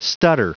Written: stutter

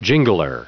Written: jingler

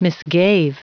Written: misgave

